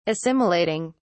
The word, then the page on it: assimilating: British English